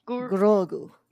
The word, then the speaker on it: Grogu: Grogu.